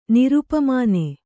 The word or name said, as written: ನಿರುಪಮಾನ